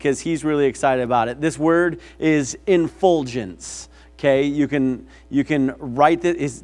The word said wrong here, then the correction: effulgence: Effulgence, not infulgence, sorry, effulgence.